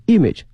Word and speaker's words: image: taste 口味创新 and image building，后面这个词非常重要 image I-M-A-G，首先它的发音呢，它的重音在前面 image，不要把重音放到后面了哈。